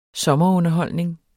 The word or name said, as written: sommerunderholdning